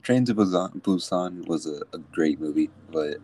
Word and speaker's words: Busan: Train to Busan? – Yeah. Or Busan.